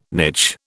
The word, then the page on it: niche: British English